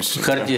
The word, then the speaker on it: Хартия: на книжную выставку, одну из крупнейших Европы, которая одним из партнеров была, если правильно говорить, поправьтесь, Хартия или Харта 77, их организация правозащитная. – Хартия 77, вот.